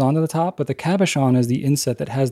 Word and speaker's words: cabochon: Cabochon.